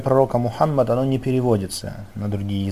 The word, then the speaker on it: Мухаммад: Из-за этого человек должен произносить Мухаммад именно как арабы произносят, то есть удваивает букву М, буква Х, она мягкая, то есть Мухаммад так говорит.